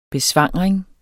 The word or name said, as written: besvangring